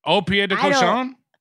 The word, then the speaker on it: Au Pied de Cochon: I'm gonna absolutely butcher the name because it's French and Au Pied de Cochon. – Yes. – Wow. – You nailed it.